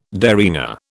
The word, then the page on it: derringer: British English